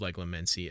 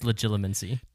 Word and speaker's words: Legilimency: I did not dare speak to you of the mission with which I knew you had been entrusted in case to use Leglamency against you. What? – Leglamency? – Isn't that what it is? I thought I actually said it right for once. – No, it's legilimency.